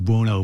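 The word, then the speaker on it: Bounou: even though it's pronounced Buono, but it's on its back, it's Bono.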